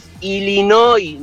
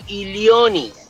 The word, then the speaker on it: Illinois: Es Illinois, no Illinois.